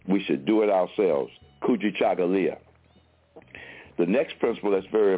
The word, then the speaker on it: Kujichagulia: The second principle of Kwanzaa is a principle called Kujichagulia.